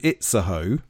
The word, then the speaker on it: Itzehoe: You get a couple of trains to this place called Itzehoe. I think that's how you say it.